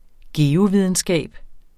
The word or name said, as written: geovidenskab